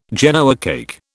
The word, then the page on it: Genoa cake: British English